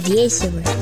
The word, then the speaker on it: веселы: Именно такое ударение в слове зафиксировано во всех авторитетных источниках. Запомнить это поможет небольшое двустишье. Пели песни вместе мы, были очень веселы. Мы веселы.